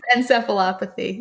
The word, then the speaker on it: encephalopathy: encephalopathy. So I think it's encephalopathy. Yeah. – Encephalopathy. It sounds like a Sesame Street word. Encephalopathy. You got it.